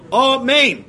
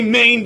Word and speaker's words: amen: Okay, you should do amen. That is the correct way to say it. Don't cut off the nun. Don't say main. Don't cut off the aleph. Make sure you pronounce it correctly.